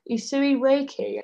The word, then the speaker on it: Usui Reiki: Usui Reiki.